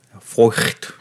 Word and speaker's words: Feucht: F-E-U-C-H-T. – 네. – Foyt. 약간 이런 독일 말인가요? – 네.